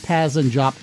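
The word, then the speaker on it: Pazz & Jop: And no, I didn't say that wrong. The Village Voice calls the poll Paz and Jop, in homage to a long-gone magazine named Jazz and Pop.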